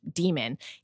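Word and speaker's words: dæmon: It's spelled like Damon, but it's demon.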